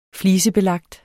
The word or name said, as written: flisebelagt